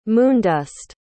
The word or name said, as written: moondust